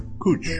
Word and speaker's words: cwtch: Words like C-R-W-T-H, pronounced proof. It is a Welsh stringed instrument, and C-W-T-C-H, it can mean either a hiding place or a gentle hug, and it's pronounced cooch.